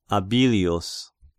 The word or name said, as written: Abilius